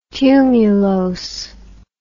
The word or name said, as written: tumulose